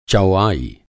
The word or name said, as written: चौआई